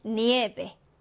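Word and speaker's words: nieve: 네, 끝에서 두 번째 음절의 강세가 오게 됩니다. 그래서 니에베 그리고 유비아라고 읽어주시면 돼요.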